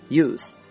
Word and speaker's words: youth: 还比如说年轻的名词叫做 youth y-o-u-t-h，这里面大家注意啊，不要读成 youth，这样的话你就变成了使用那个单词，后面这个 th 它是一个咬舌的音。